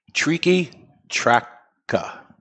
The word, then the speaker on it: Triqui Traca: All right, the correct pronunciation is Mi Querida Tricky Tracker. – Tricky Tracker. – The Tracker is pronounced T-R-A-H hyphen K-A.